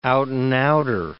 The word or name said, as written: out-and-outer